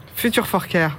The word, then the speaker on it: Future4Care: On prononce comme ça Future for Care, parfaitement. – le prononcer. Bah, c'est, une dénomination anglophone, hein. on est obligé de de la prononcer comme ça.